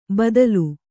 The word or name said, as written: ಬಾದ್ಲಿ